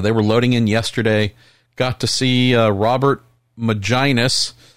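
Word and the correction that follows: Megennis: I think as we figured out how to pronounce his name last time he was on the show, Robert McGinnis, saw him first.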